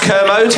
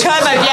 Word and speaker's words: Kermode: Watch your back, my own commode. We're coming for you. – It's pronounced Kermode. – Kermode, yeah.